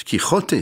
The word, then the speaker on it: Quichotte: Son attaque des moulins à vent, tirée de la burlesque de Don Quichotte, de Quichotte, Quichotte, c'est écrit même avec un X avant la prononciation. Évidemment, ces lettres n'est pas semblable dans les pays, mais nous on dit Quichotte, on va dire ça comme ça.